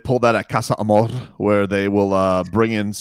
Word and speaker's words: Casa Amor: You got to say Casa Amor. – Amor. Yeah, that's how it's said.